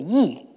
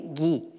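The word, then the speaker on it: yi (義): ce que les Chinois appellent euh, yi. Hein. Et euh, en prononciation japonaise, qui vous est peut-être plus familière, c'est gi.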